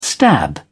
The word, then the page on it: stab: British English